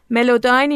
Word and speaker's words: Melodyne: ملوداین اینجوری تلفظ میشه: MELODYNE.